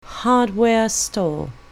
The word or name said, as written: hardware store